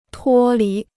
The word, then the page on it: 脱离: tuō lí